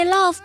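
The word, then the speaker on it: Lauv: 而我们今天要给大家介绍的这一位呢，音乐作品自然是没话说了，她就是有六公主之称的 Love。对于六公主这样一个绰号，其实起名的原因也非常简单，因为 Love 本身的名字的英语发音就很像我们中文当中的六。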